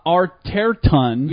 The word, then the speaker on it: Arterton: Gemma Arterton.